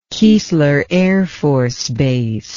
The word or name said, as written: keesler air force base